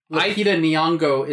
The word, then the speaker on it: Lupita Nyong'o: And even though, like, the script kind of fails her in the third act, Lupita Nyong'o is how you properly pronounce her name.